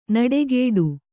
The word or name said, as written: ನುಡಿಗೊಡು